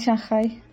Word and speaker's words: Shanghai: Oye, le dijiste Shanghai. ¿Es así la correcta pronunciación? Yo siempre me creí que era Shanghai, weón. – No, es Shanghai.